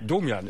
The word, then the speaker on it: Domian: Ja, Domian ist es, ist richtig ausgesprochen.